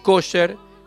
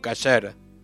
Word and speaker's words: kosher: Por el ashkenazí, por ejemplo, dice kosher, mientras que el sefaradí dice kasher.